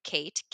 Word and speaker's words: Kate: And my username is @Mare, M-A-R, Kate, K-A-T-E, 12.